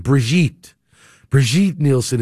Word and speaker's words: Brigitte Nielsen: And then Stallone ended up starring with Bridget Nielsen. Brigitte Nielsen is the actual correct pronunciation to say her name.